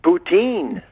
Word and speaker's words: poutine: Poutine. – Poutine, hein? – Oui, Poutine, c'est euh, comme. c'est Puis parfois, je vais l'avoir, je vais le voir inscrit P-O-U-T-E-E-N, comme teenager, teen, Poutine.